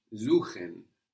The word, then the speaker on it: suchen: Suchen. – So an S vowel syllable gives us a Z sound.